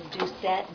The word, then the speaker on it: Doucette: Cecilia, C-E-C-E-L-I-A. My last name is Doucette, D-S, and David, O-U-C-E-T-T-E. – Jean Dolgan, G-E-N-E. Last name, D-O-L-G-I-N. – Joe Solid, J-O-S-O-L-E-T.